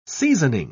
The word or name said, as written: seasoning